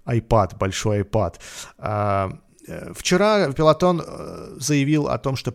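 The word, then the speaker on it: Peloton: Вчера, кстати, Пилотон. А мы э, думаю, и эту тему затронем. Прямо сейчас ее можем затронуть. Вчера рухнули акции Пелотон. Пелотон, да, пишется.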